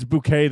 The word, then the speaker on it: Bucket: She says, it's bouquet, though. She's like, it's pronounced bouquet.